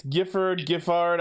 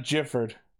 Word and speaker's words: Giffard: It's Giffard. I don't know how they're going to pronounce it, but yeah. It's definitely not Gifford.